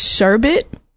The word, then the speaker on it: sherbet: Somebody told me it's sherbet. There's no like second R in sherbet, but I've been saying sherbet literally all my life.